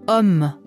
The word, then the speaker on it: homme: Das französische H wird nie ausgesprochen. – Homme, hôtel, horizon.